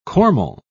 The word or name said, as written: cormel